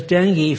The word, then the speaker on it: dengue: And then there is dengue fever. If you see that, it's spelled D-E-N-G-U-E. It's pronounced dengue.